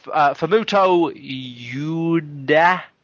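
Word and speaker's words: Fumito Ueda: I don't know how to say his name. That was very bad. – That's code for the PS4. – No, yeah. That's code for we started again. So, yes. The game creator Fumoto Yuda.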